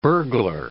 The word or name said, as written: burglar